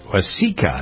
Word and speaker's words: Waseca: Jennifer Horn, Victoria Keith, and Doug Steffen being heard for the first time on KFOW AM and FM in Waseca, I believe is how you pronounce Waseca, W-A-S-E-C-A, Minnesota, far from where my sister lives.